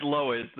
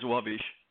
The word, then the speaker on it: Lowisz: The American way is Lowisz. The Polish way is Lowisz.